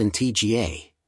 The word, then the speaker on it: Antigua: Antigua, pronounced in TGA, and Barbuda, Barbuda, is located in the heart of the Caribbean Sea.